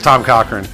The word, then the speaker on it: Cochrane: No, it's Tom Cochrane.